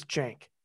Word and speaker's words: Cenk: And I'm told it's Cenk. It's spelled C-E-N-K, Cenk Kocak from Turkey, but I'll get confirmed by him.